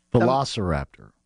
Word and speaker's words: Velociraptor: Velociraptor. – Is that how you say it? – Yes.